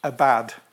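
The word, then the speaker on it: abad (עבד): The word work in the Hebrew it's quite funny actually, it's spelled A-B-A-D, abad. And you think, work's bad then. But it's actually pronounced abad.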